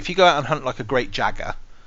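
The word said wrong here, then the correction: Jagras: you might have to correct my pronunciation on this one, Clarky. – All right. Yeah. – Jagus, is it? – Jagrus. – Jagrus, right? You go and hunt one of them, and you chop it up, and that battle might take you 20 minutes.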